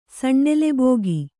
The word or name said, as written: ಸಣ್ಣೆಲೆಬೋಗಿ